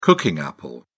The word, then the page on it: cooking apple: British English